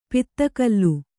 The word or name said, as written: ಪಿತ್ತಕಲ್ಲು